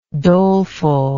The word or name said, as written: dolefulness